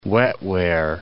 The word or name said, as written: wetware